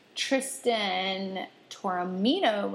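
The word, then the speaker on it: Tristan Taormina: I mispronounced the last person's name. Tristan Toramino was her name of Sex Out Loud.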